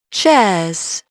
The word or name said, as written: chairs